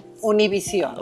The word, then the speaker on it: Univision: And this one, Univision. – Univision. Yeah, see, when you said it that way. – Uno, like number one.